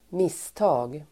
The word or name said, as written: misstag